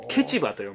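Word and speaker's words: 結馬: で、今僕らのいるとこはですね、結ぶ馬と書いて、ケチバと読む。 – あ、ケチバと読むんですか。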